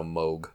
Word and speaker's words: Moog: On the internet, I just asked it to pronounce it, and it says it's pronounced M-O-W-G, so Moog. – Moog.